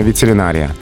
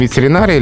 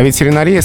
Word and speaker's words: ветеринария: А, хотя литературная норма ветеринария. И можно понять, почему именно такое ударение правильное. Русский язык вообще не любит ударение на концах слов, особенно длинных слов. В отличие от французского. Он стремится, да, ударение ближе к середине слова перенести. Поэтому ветеринария — литературная норма, а ветеринария, скорее всего, профессиональный вариант.